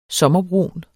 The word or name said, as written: sommerbrun